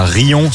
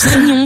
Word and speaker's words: Riom: Il y a Rion, ça s'écrit R-I-O-M. – Et alors? – Et pourquoi vous faites ça? – Mais ça se prononce Rion, c'est on fait ce qu'on veut dans le 63.